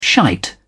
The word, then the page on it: shite: British English